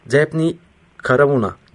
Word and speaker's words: Daphne Caruana: Musa Anter ile Malta'dan Daphne Caruana, değil mi? Doğru telaffuz ettim.